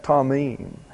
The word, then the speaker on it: tamim: The word for blameless or perfect is tamim. T-A-M-I-M, pronounced tamim. And the word for ways is the plural of an old friend, Derek. D-E-R-E-K.